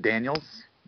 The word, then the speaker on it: Daniels: They brought back Chase Daniel. – Oh boy. It's Daniels, by the way, just to clarify.